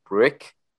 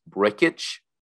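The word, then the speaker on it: Brkic: I was planning on just calling him Brick, from Anchorman, because that would have been kind of funny. But it turns out his name is pronounced Brickich.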